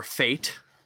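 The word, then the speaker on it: Fête: after FET or Fate, as they like to call it. We went over this. It's okay, pronounced FET in French, and then we went over this on like an episode a year ago.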